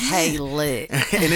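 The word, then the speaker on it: Kalita: Kaylit. – And it is short for.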